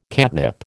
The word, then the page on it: catnip: American English